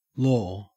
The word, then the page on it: law: Australian English